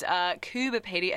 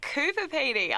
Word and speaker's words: Coober Pedy: Went there last year and was shocked that it's Coober Pedy and not Coober Pedy. I feel like we've all spent our whole lives saying it wrong.